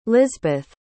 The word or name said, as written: lisbeth